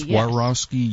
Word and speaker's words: Swarovski: It's uh, Swarovski. Swarovski. Is it? Am I pronouncing that correctly? Swarovski. – Swarovski yes.